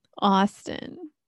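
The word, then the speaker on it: Awsten: Do you want me to spell Austin for you? Because it's spelled real funky. – Is it A-U-S-T-E-N? – Worse. – Oh, God. – A-W-S-T-E-N. T-E-N. – A-W-S. – Austin.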